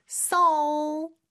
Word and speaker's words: salt: 그리고 중간에 저 L 발음, 특히 이제 끝에 오는 L 발음은 L 발음이에요. 그래서 혀는 앞으로 쭉 와서 윗니 뒤쪽에 붙지만 혀의 뒤쪽, 목구멍 쪽은 약간 목구멍을 막는 느낌으로 L 요런 느낌으로 해주시면 됩니다. 그러면서 salt 살짝 T 발음 해주면 되겠네요.